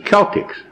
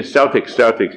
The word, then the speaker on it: Celtic: Celtic 啊。可是这个 Celtic 这个字 C-E-L-T-I-C-S 它正确的念法或者说古典的念法应该是 Celtic C-E-L 念 Cel 不念 Cell，但是美国人向来英文不太会读，他们念 Celtic 就算 Celtic，其实它原来的发音应该是 Celtic。